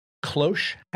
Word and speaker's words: cloche: And the two women who uh, very obviously look like models, they're wearing cloche hats.